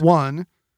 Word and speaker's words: won: One. Could be Korean currency. – Wan. – It's spelled W-O-N, but pronounced one.